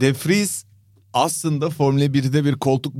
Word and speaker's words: de Vries: DeFries falan mı düşündün? – DeFries yok. DeVries. DeVries.